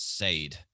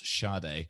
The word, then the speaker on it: Sade: but she pronounced it as Sade. And this is a song by Sade, which we all know is actually pronounced Sade.